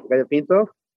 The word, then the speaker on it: gallo pinto: Yeah, gallo pinto.